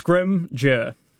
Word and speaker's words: Scrimger: So it's pretty simple. Mm-hmm. Two syllables. Yep. Don't overthink it. – Yes. – Scrimger.